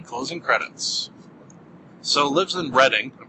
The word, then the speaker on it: Reading: See, it's spelled Reading, but I believe it's pronounced Reading. It's like a suburb of London.